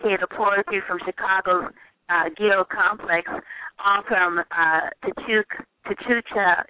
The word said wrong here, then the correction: Tia Chucha: is that how you pronounce it? – Tia Chucha Press.